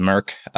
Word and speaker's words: M3RC: M3RC, yeah. Pronounced Merc. – Yep, it was Merc.